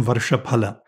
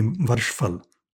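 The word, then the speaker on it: Varshaphala: In you know, with a classical Sanskrit pronunciation, Varshapala. Varsha meaning a year and pala meaning fruit. In modern North Indian pronunciation would be Varshfal.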